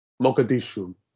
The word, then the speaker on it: Mogadishu: Mogadishu, you're correct.